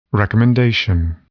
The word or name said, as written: recommendation